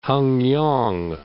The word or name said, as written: Hengyang